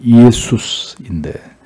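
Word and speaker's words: 예수스인데 (Ἰησοῦς): 예수, 정확한 발음은 예수스인데 여호와는 구원이시다라는 뜻으로 당시 유대인들에게 이 이름은 굉장히 흔한 이름이었습니다.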